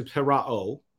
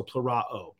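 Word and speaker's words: plerao (πληρόω): And the way it was pronounced is actually plerao. So the omega is a long O, the omicron is a short O, so plerao.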